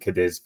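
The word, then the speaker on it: Cádiz: So, I think if you're Spanish, that's how you pronounce it. But I think Cadiz, for sake of ease, is fine with me.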